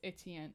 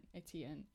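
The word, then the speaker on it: Étienne: I love how she breaks up his name in three syllables. Like she says on page 253, my feet trip in three syllables. Etienne, Etienne, Etienne.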